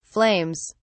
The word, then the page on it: flames: British English